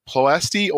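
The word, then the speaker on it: Ploiești: who'd been shot down over the Ploesti oil fields. Did I say that right? – Yes, you did.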